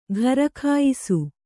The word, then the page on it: ಘರಖಾಯಿಸು: gharakhāyisu